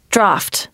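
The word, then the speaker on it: draught: Q-U-E-U-E is pronounced Q. D-R-A-U-G-H-T is draft. And C-H-A-O-S is chaos. There are also problems with silent letters. S-U-B-T-L-E is subtle. H-E-I-G-H-T is height.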